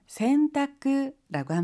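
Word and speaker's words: 洗濯: 일본어로는 센타쿠라고 합니다. 씻을 선이라고 하는 한자가 있어요. 근데 우리는 세라는 한자음을 취하고요. 일본어에서는 선이라고 하는 한자음을 취해요. 그래서 세탁을 센타쿠라고 발음한답니다.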